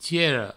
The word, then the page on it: tiera: tiero